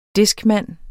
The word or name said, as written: discman